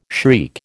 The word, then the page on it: shriek: American English